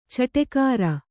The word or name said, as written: ಚಟಗಾರ